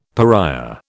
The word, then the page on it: pariah: British English